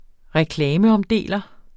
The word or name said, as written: reklameomdeler